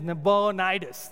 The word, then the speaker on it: Nabonidus: His name is Nabonidus.